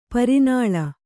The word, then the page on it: ಪ್ರಣಾಳ: praṇaḷa